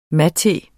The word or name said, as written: MATEEN